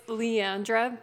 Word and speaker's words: Liandra: Is that how you say your name? Liandra? – That's how my dad says it. – Oh, really? Oh, shit. – It's Liandra, but I'll answer to anything.